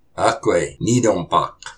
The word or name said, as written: Aquay, nitôpak.